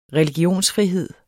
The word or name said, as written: religionsfrihed